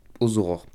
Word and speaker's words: Ozoror: Là, on vient d'écouter le morceau Vite, c'est un extrait de l'EP Aux Aurores, ça s'écrit O-Z-O-R-O-R. Aux Aurores.